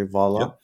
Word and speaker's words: Valla: And there's a very important character that you talk about in your book, Lorenzo Valla, if I'm pronouncing the name correctly, Valla. Yep.